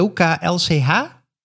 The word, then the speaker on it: OKLCH: Je kunt die, eh, wat is het, OKCL. nee, OKLCH Zeg ik dat goed? Ja.